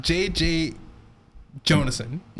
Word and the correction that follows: J. Jonah Jameson: J. Jonah Jameson.